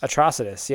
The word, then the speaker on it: Atrocitus: Atrocitus? – Atrocitus, yeah.